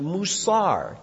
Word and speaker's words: musar: It's a Hebrew word pronounced musar, M-U-S-A-R.